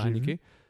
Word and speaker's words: Haneke: Mais t'as Funny Game du, de la d'origine du réalisateur de Michael Anaki. Je pense que je, sais pas si je le prononce bien, Michael Haneke.